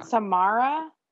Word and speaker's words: Samara: That one I know you're pronouncing wrong. – Samara.